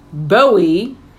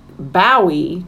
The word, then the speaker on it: Bowie: And he goes, oh, his stage name is pronounced Bowie to rhyme with Doughy or Joey. Anyone who pronouncing it Bowie to rhyme with Howie or Towie is wrong.